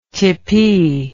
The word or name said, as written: tippee